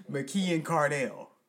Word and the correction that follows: Keenan McCardell: The Keenan McCardell? – Oh, see, I even said his name wrong.